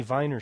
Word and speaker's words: Weiner: I prefer Viner, which is the traditional German pronunciation of the former congressman from New York, his name. I prefer Anthony Viner to the popularized Anthony Weiner.